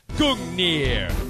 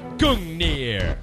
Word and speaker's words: Gungnir: but what you didn't check out was the freaking pronunciation, and that's Gungnir. That's right, Gungnir.